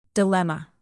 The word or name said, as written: dilemma